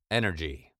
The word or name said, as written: energy